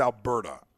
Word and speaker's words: Alberta: University of Alberta Professor Timothy Caulfield, who specializes in health research, blasted Adam's tweet. – By the way, if we're going to make fun of the way I pronounce words, it's Alberta.